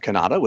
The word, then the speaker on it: Cannata: I'm at Jeff Cannata, which is spelled with two N's and one T.